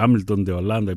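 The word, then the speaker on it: Hamilton de Holanda: Άμιλτον Ντε Ολάντα γράφεται, Άμιλτον Ντε Ολάντα η προφορά.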